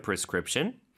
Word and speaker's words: prescription: 그리고 마지막에 prescription 여기는 script 요 부분에 강세가 있죠. prescription 내에서 이음절에 강세가 있는 거 요거 기억하시면서 우리 큰 목소리로 따라해 볼게요.